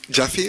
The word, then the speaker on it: Japhy: Jaffy es J-A-P-H-Y. – J-A-P-H-Y.